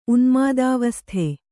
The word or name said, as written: ಉನ್ಮಾದಾವಸ್ಥೆ